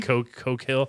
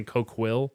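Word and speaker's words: Coquille: Coke Hill. It's funny. I have no fucking idea how to say this. I kept saying Coke Will. – Yeah. – And that's wrong.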